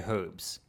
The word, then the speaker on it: herbs: Just like I say herbs and everybody thinks, yeah, everybody, I'm like, it's an H.